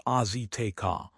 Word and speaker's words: Azteca: The Mexican Football Federation announced late this afternoon that the final round of the CONCACAF qualifiers will be held at Azteca Stadium, pronounced Azteca, in Mexico City.